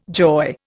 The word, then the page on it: joy: Indian